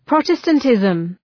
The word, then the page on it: Protestantism: {‘prɒtəstən,tızm}